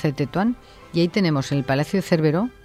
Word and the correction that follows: Cervelló: de Cervelló. – Cervelló. – Es que no lo digo bien nunca.